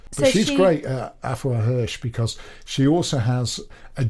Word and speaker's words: Afua Hirsch: How do you say that? Afua Hirsch, I think. – Afua Hirsch. – Yeah.